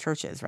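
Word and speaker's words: Chvrches: I think it must be just pronounced Churches, right? But the U is a V.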